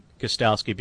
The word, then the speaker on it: Gostkowski: With Stephen Gostkowski, Patriot place kicker, and I say your name, Gostkowski, because I was told last year before my first Patriots broadcast that either leave the T or the K silent, whichever you prefer.